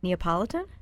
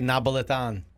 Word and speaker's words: Neapolitan: Neapolitan? – See, I say Napolitan.